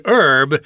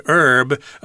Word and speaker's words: herb: Now, here we've got the word herb. Uh, some people pronounce this as herb. I think uh, I tend to say herb myself.